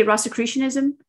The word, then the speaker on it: Rosicrucianism: Rosicrucianism. So Rosicrucianism is a mystical element tradition within Christianity that it's called the Holy Cross, the Rosy Cross. Okay. And Rosicrucianism.